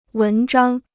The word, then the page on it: 文章: wén zhāng